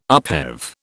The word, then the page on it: upheave: British English